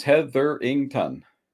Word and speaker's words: Hetherington: Just the way it sounds. Hetherington.